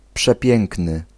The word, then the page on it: großartig: /ˈɡʁoːsʔaːɐ̯tɪç/